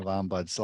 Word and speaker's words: ombuds: And first thing, you got the hard part done, which is the proper pronunciation of ombuds. A lot of people added an S or a Z in the middle of it. So you got that right.